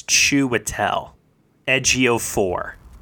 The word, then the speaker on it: Chiwetel Ejiofor: I gotta be very kind to you right now, Will, and just say it's Chiwetel Ejiofor. – How did I pronounce it? – Not that.